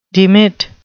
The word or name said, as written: demit